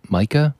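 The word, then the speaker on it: Maika: Did I pronounce that right? Micah? Micah Monroe? She was in The Guest, and It Follows. – Yeah, I think you did say that right.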